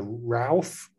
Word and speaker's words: Routh: It's a detached four-bedroom house in, I can't pronounce this either, Routh, R-O-U-T-H, Routh.